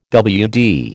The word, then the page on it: WD: American English